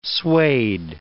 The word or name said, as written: suede